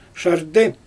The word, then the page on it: ʃardɛ: Xyzyl